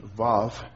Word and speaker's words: Vav: And the section beginning with verse 41 has this W-A-W. Again, that's pronounced Vav.